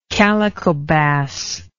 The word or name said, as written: calico bass